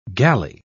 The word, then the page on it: gally: British English